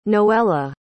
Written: noella

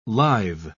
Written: lithe

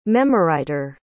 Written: memoriter

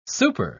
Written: super

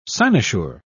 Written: cynosure

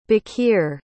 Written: baqir

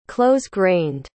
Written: close-grained

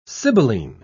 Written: sibylline

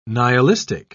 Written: nihilistic

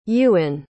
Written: euan

